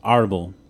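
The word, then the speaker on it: árbol: Like, well, it potentially is because the accent is on the first syllable. Arbol.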